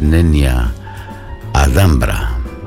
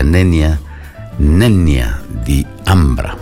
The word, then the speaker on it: Nenia di Ambra: Alessandra nos va a interpretar un tema escrito en latín que es Nenia Adambra, que pronunciado correctamente sería Nenia di Ambra, que traduciendo al español sería un lamento para el ámbar, para la piedra, para el mineral, ¿no?